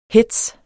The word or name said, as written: HETZEN